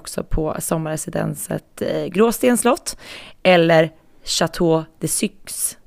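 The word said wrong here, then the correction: Cayx: Sa jag rätt nu Jenny? Nej, Chateau de Caix.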